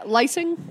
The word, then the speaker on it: Lyseng: Yeah, Lyseng. – Lyseng? Yeah. I'm horrible with last names. – It's a weird last name. Yeah. It's like pronounced differently than it's spelled.